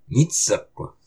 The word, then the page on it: Mitsuq!: Algonquian